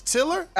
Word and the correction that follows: Triller: It's Triller. – Oh, Triller. All right.